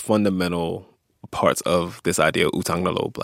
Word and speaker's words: Utang na loob: Utang na loob.